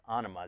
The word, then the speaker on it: onoma: And the Greek word for name is onoma, or you might, if you were looking at it, you might pronounce it onoma, but it's onoma is really how you say it.